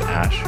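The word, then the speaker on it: Æ: It's just X and then the AE is pronounced Ash.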